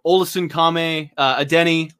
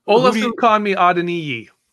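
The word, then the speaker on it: Olasunkanmi Adeniyi: Olesen Kame Adeniyi. – Okay. – I learned how to say his name, so I say every chance I get. – I thought it was just a Denny.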